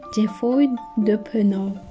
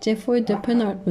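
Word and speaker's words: Geoffroy de Pennart: Neydi Elif Çağla'nın söylediği? Cefoy de Peno. Cefoy de Peno. Biz böyle nasıl söylesek acaba? Cefoy e, de Penart mı desek? Cefoy de Penart mı desek diye baya bir Çağla'nın bize gönderdiği e, ses kaydının üzerine çalıştık aslında.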